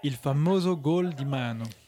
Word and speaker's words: gol: Donc nous on dit gol. Là c'est écrit G-O-A-L, mais parfois nous on peut l'écrire G-O-L tout simplement, okay, vu la prononciation, pour dire but.